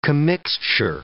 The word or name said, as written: commixture